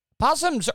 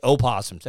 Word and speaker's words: possum: Possums are the... And I hate when people say opossums. It's a possum. The O is silent.